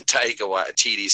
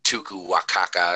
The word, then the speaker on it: Taika Waititi: And then Taika Waititi started. Said his name right. Fuck him. Fucking Taika. – I know. I was going to say, you started saying his name right. – Fucking Tuku Wakaka or whatever his name is.